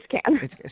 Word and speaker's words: Cannes: Yeah, but the pronunciation, is it Cannes or Cannes? – It's been driving me nuts my whole life, and I've never been there. – Yeah, it's can. It's can.